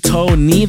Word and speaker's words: Toeknee: Before we get into it, make sure you guys go follow him on Instagram at DJ Tony. That's DJ T O E K N E E. The K is silent.